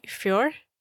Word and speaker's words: Fjord: I hope I'm saying it right, Fjord? – Yeah, that's right.